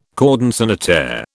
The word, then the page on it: cordon sanitaire: British English